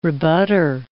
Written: rebutter

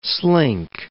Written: slink

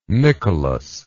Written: nickelous

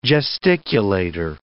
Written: gesticulator

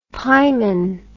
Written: pieman

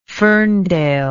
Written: Ferndale

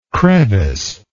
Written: creviced

